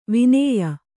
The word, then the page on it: ವಿನೇಯ: vinēya